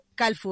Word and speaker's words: Kalfu: Calfu, ¿verdad? ¿Lo dije bien? perfecto.